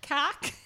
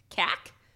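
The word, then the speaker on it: Khac: Cac. It's K H A C. Cac. – Cack and balls? – Oh, when?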